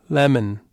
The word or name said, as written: lemon